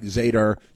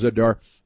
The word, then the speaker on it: Zadar: It looks like Zadar. It's pronounced Zadar.